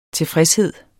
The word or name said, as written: tilfredshed